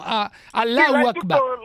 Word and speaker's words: Allahu Akbar: È curiosa anche la pronuncia senegalese, no? Allahu Akbar. – L'hai detto giusta.